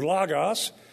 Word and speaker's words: logos: So logos, or logos, as the Greeks would pronounce it, is speech.